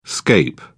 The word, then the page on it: scape: British English